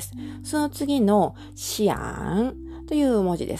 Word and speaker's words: 想: えーこの漢字が当たっているんですが、発音記号は xiang と書いて、シアンと読みます。第三声。これも低く下がる、落ちる音です。をシアン。